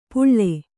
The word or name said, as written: ಪುಳ್ಳೆ